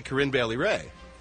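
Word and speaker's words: Corinne Bailey Rae: But I said it right, Corinne Bailey Ray.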